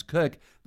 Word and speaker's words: Koch: Or my last name, spelled K-O-C-H, which we pronounce Cook, that's just bizarre, which is why I go by Dr. Kathy professionally.